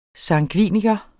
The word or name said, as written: sangviniker